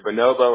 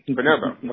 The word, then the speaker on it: Bonobo: It's either Bonobo or Bonobo, depending on who you ask. Bonobo.